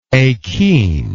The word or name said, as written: akene